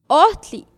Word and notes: ohtli: Náhuatl

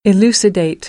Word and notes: elucidate: American English; ə - ɪ

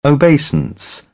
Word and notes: obeisance: British English; əʊ

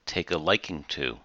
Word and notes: take a liking to: idiom

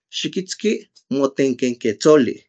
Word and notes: xikitski motenkekentsoli: Náhuatl

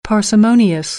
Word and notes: parsimonious: American English

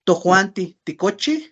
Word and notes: tojuantij tikochij: Náhuatl

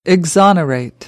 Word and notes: exonerate: American English; ɑː

